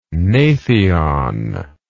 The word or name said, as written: gnathion